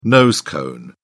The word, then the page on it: nose cone: British English